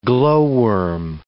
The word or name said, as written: glowworm